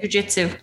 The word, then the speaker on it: jiu-jitsu: Jiu-jitsu.